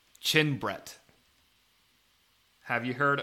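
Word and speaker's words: Chin-Bret: So Chin Brett spelled like Chin, so C H I N dash B R E T, Chin Brett.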